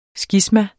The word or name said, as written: skisma